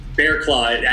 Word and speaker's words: Barraclough: Barraclough. I know it's spelled like Barraclough, but it's just Barraclough.